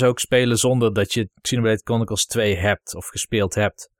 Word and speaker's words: Xenoblade Chronicles: Voor Xenoblade Chronicles 2, die hier niet bij zit.